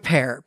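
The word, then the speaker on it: Pär: Let's get to the next one, which is Pear, which is spelled P-A-R. The A has an umlaut on it, so it's a long A in English. It is not pear like the fruit, but Pear. Pear, much like the narrator, much like Bartolomeo, has some physical abnormalities.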